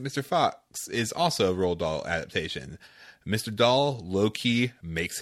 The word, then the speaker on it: Roald Dahl: and he pointed out that Roald Dahl, I got his name right, also did Fantastic Mr. Fox.